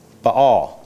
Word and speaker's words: Baal: Baal. Now, I say Baal. Don't laugh at me. Some people say Baal. I don't know what the right pronunciation is. I'm correct? Yes, correct. Baal, god of the Canaanites.